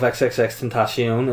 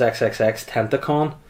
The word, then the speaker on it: XXXTentacion: Davey Witt has said, your pronunciation of XXXTentacion is outstanding. I actually thought it was XXXTentacon.